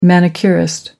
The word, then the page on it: manicurist: American English